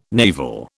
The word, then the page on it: navel: British English